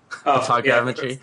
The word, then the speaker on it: photogrammetry: Photogrammetry? – Photogrammetry.